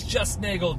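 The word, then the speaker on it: JustNeagle: That's JustNagel, just.nagel. Also the JustNagel website, and that's NEAGLE, spelled like beagle, pronounced like bagel, which I actually think I'm going to go get some bagels for the office this morning.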